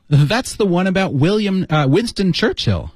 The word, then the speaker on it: Churchill: 应该读成 Churchill. – Yeah. – 一个是 Church, 一个是 Ill, 读成 Churchill. – Hill, Churchill.